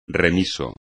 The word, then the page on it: remiso: espagnol